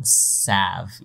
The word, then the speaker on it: salve: Cooling salve. Yeah. – I don't think you pronounce the L in salve either. Isn't it salve? – No, it is salve.